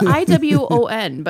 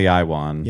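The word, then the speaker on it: Iwan: No, because it's Iwon, but I don't remember how it's pronounced. – Iwon. – It's like Welsh or something. – Yeah, it's definitely Iwon.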